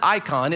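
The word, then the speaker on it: eikon: It's T-S-E-L-E-M, not that you were paying that much attention or even that you care. In the Greek, it's E-I-K-O-N. You'd pronounce it icon. It's where in our English word we would get the word icon, I-C-O-N, right?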